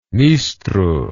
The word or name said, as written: nistru